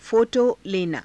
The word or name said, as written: pho_to lenaa